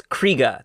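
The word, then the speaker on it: Krige: Yeah, it's Krieger. There's no R in the end. – Alice Krieger.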